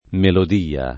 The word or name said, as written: melodia